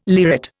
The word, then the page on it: lyrate: Indian